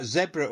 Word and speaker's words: Zebra: And then before that, Zebra or Zebra, whichever way you want to pronounce it, with Tell Me What You Want.